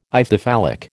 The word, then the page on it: Ithyphallic: American English